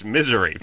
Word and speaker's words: Missouri: And she pronounced, the way she pronounces Missouri is misery, basically.